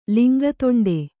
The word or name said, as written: ಲಿಂಗತೊಂಡೆ